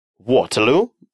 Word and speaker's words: Waterloo: Waterloo произносится по-английски.